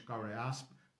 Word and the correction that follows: Karl Jaspers: Karl, Karl Jaspers, pô, eu treinei a pronúncia e errei. é Karl Jaspers, alemão.